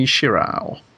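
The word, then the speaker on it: Shirow: Masamune Shirao. I've no idea if I'm pronouncing that right. – Is it Shirao or Shiro? I think it's Shiro.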